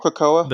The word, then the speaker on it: Krakoa: Krakoa.